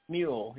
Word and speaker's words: Smule: S-M-U-L-E. – Oh. – How do you say it? – Mule, yeah. Mule.